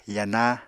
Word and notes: yana: Apatani

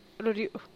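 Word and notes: rudiuu: Teotitlán del Valle Zapotec

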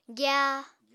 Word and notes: gya: apatani

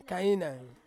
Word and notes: kainaing: Ansus

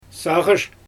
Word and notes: saaGysh: Xyzyl

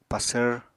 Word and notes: pasvr: Apatani

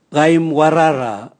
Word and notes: gaimwarara: Raga

